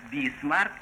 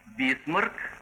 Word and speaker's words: Бисмарк: Так, «бисмарк» — это произношение по-иностранному. А по-русски «бисмарк» — как «насморк».